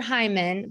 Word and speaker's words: Heuman: So it's Heather Heumann, but it's spelled H-E-U-M-A-N.